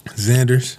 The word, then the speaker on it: Zanders: And today we have Brandon, how you say your last name? – Zanders.